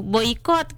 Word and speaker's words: boikot: Bukan bokiot, emang ejaan si orang ini ya, bukan saya yang salah ngomong. Emang dia nih yang sebut begitu, boikot kan. Nah itu yang benar, yang salah orang ini gitu.